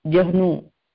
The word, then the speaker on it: Jahnu: This Muni, how do you say his name properly? – Janu Muni. – Maybe if you spell, I can understand. – J-A-H-N-U. Janu.